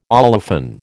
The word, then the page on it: olefin: American English